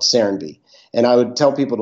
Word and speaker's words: Serenbe: Say the name again, or maybe spell it if you can. – Sure. Serenby. S-E-R-E-N-B-E. – Serenby.